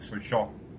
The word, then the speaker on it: Sochaux: Contra el Sochaux, así se pronuncia.